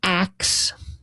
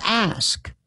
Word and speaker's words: ask: Well, the pronunciation axe instead of ask is not a, as I learned, it is not a modern mistake. It is it's not slang. It's not a corruption or a sign of ignorance. In fact, it is older than the modern pronunciation of ask.